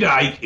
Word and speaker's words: Icke: David, and his last name is I-C-K-E. David Ick? – David Icke.